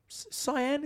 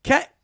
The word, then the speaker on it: Cian: Is this Cyan? You put like the colour? Like a light blue? Is that how I pronounce his name, Cyan? – Kane. – Well, you put an I before the A. – I can't spell. – That's how you spell Kane. C-I-A-N.